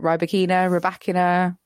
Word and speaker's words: Rybakina: Is her surname, it's Rybakina? – Correct, Joel. Yeah, I think we're saying it right. Rybakina.